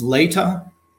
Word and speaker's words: Latah: It's Lata. L-A-T-A-H Books was the publisher for this first one.